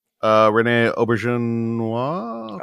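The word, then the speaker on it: René Auberjonois: Rene Auberjonois.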